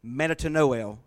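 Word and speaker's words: metanoeō: And the word beside repentance is the Greek word, metanoeo, is how it's actually pronounced, as close as I can get it. Metanoeo, and it's actually noel, with the L, it's had an L sound for some reason, but that's the Greek word.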